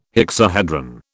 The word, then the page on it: hexahedron: British English